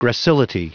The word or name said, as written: gracility